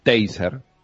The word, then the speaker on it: Taser: Taser, es la forma correcta de pronunciarlo, en la ciudad de La Plata.